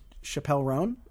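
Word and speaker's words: Chappell Roan: Chappelle Roan. Am I getting that right? – I believe so.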